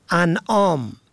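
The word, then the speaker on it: اَنعام: ان آم و ان آم، این دو واژه در تلفظ و معنا متفاوت است. انعام با فتح اول جمع نعم به معنای چارپایان است، اما انعام با کسر اول به مفهوم بخشش است.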